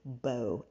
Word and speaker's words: Mbow: You can find me on social media at Nesia, N-E-S-I-A, Bow, M-B-O-W. The M is silent, on Instagram.